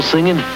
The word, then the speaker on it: singing: I figure it might help you to get the people singing. The word is singing. Singing. That's what I said. Ing. Singing. You really ought to pay more attention to your pronunciation.